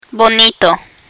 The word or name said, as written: bonito